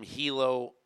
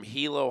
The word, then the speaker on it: Hilo: This high school kid from Hilo High School in Hawaii, am I pronouncing it right? I think it's Hilo instead of Hilo.